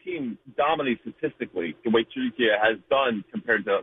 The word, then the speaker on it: Türkiye: The way Turkish people pronounce it is actually the way it's said, Türkiye.